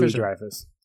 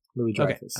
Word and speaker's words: Louis-Dreyfus: Louis-Dreyfus. Louis-Dreyfus.